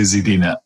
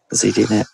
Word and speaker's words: ZDNet: How do you say it? – I say ZDNet. – ZDNet. I say ZD, yeah. Still weird.